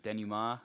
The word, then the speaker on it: denouement: And it's like a good, like, what is it, denouement? I'm sure I said that wrong. It's like... – No, that sounds right.